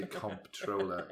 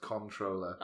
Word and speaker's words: comptroller: You don't say comptroller. It's pronounced controller.